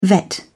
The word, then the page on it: vet: British English